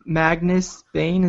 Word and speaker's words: Magnus Bane: And then is there something on Magnus Bane? Is that how you pronounce his name? – Yes.